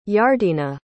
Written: yardenah

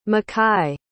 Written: makkai

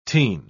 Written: teen

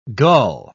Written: gull

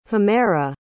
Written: humaira

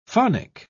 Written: phonic

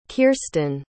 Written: kiersten

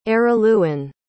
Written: Araluen

